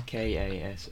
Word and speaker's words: Kasalis: It's Kasalis.